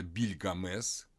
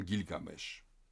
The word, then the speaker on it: Гильгамеш: Потом это длинное имя редуцируется на просто Бильгамес, Бильгамес, или затем Гильгамеш, уже в акадском произношении.